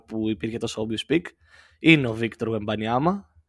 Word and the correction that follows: Wembanyama: Ε, Εντάξει, βασικά όχι, είναι Γουεμπανιαμά. Γουεμπανιαμά, επειδή είναι Γάλλος.